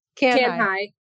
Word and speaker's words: Kanhai: S-A-B-I-T-A, Kanhai. Did I say that right? Kanhai? – Good enough. Kanhai. – It's K-A-N-H-A-I.